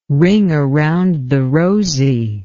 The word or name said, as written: ring around the rosey